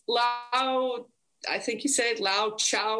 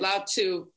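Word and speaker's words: Lao Tzu: But, you know, I talk about how if you're living in the past, so it's a Lao Chow or something, but you spell it T-Z-U, right? Lao Chu.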